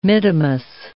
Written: mittimus

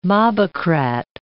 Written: mobocrat

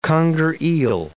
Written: conger eel

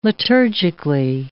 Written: liturgically

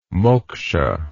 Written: moksha

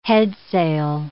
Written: headsail

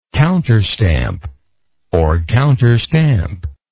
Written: counterstamp

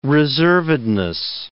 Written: reservedness

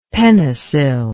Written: penicil

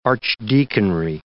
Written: archdeaconry

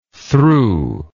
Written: thru

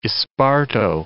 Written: esparto

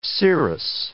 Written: cirrus

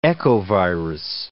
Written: echovirus

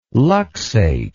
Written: luxate

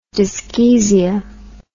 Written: dyschezia

